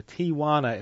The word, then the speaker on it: Tijuana: But whatever, however he pronounced his name, that was Tijuana, or Tijuana as it says also on the label, 1924.